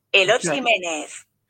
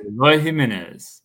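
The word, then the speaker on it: Eloy Jiménez: Eloy Jimenez. – That was too quick. – What'd he say? What'd she say? – Eloy Jimenez.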